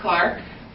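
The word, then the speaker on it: Clarke: My name's Marie Clark, but it's spelled with an E on the end, so I've lost my N with the Clark County swag.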